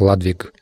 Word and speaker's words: Людвиг: На английском это произносится как «Ладвиг».